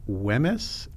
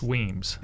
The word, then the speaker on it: Wemyss: the Civil War Letters of Henry Wemyss. – It's Weems. – It's Weems, okay. – That's why the British pronounce Weems.